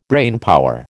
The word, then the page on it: brainpower: American English